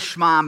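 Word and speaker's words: Shema (שמע): And the authors write, the Shema, pronounced Shema, is not actually a prayer,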